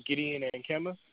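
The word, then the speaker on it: Gideon Ankama: Gideon Ankema. – Could you spell that for me? – G-I-D-E-O-N. Then A-N-K-A-M-A.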